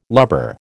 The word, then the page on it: lubber: American English